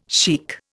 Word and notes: chic: Indian

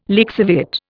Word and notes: lixiviate: Indian

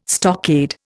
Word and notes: stockade: Indian

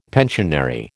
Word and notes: pensionary: American English

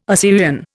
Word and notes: Assyrian: Indian

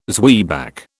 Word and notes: zwieback: British English

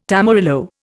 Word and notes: tamarillo: Indian

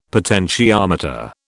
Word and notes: potentiometer: British English

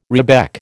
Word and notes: rebec: American English

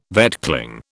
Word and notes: vetchling: British English

